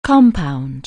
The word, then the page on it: compound: anglais